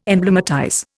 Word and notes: emblematize: Indian

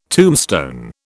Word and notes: tombstone: British English

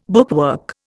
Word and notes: bookwork: Indian

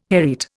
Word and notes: hereat: Indian

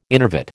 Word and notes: innervate: American English

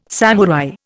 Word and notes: samurai: Indian

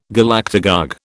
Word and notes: Galactagogue: British English

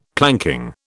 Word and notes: planking: British English

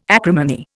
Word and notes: acrimony: Indian

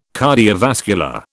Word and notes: cardiovascular: British English

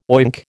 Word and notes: oink: American English

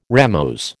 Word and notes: ramose: American English

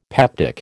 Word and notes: peptic: American English

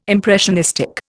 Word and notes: impressionistic: Indian

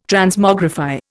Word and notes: Transmogrify: Indian

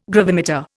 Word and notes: gravimeter: Indian

